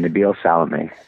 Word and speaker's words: Nabil Salameh: Nabil Salameh.